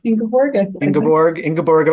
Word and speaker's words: Ingeborg: How will we be pronouncing her name? Ingeborg? Ingeborg of Denmark.